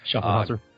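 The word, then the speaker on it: Schöfferhofer: spelled B-I-E-R. I'm just going to grab me a, and I always say this wrong, Schoenhofer, and if I said that wrong, I apologize. – Schoenhofer. – There you go. Thank you for correcting me.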